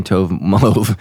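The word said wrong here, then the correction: tohu wa-bohu: but I've been saying that for a while. It's Tohu Wabohu, and it is found in Genesis 1:2.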